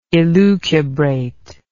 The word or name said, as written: elucubration